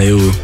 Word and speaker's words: Ewo: So that was Ewo. Am I saying that right? – Yeah, Ewo.